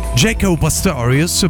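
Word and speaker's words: Jaco Pastorius: del personaggio e della musica soprattutto di Jacopo Pastorius. Jacob Pastorius, per essere esatti nella sua pronuncia, è un eh, bassista, è stato un bassista americano, John Francis Pastorius III, il terzo.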